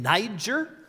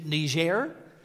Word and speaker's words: Niger: Christy has served as a missionary in Niger, or if you prefer the French pronunciation, Niger, for many years.